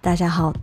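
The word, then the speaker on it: 大家好: そうなんです、ダジャハオと、大きな家好きと書いてダジャハオという中国語です。皆さんこんにちはみたいな感じですね。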